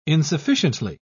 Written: insufficiently

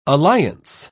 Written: alliance

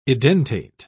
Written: edentate

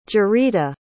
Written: jarita

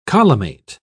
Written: collimate

